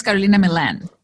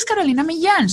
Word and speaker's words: Carolina Millan: Because I was like, I'm tired of people like mispronouncing my name. I'm just going to mispronounce it too. Yeah. And then one day I was like, you know what? No, my name is Carolina Millan. So I'm going to start saying it the right way.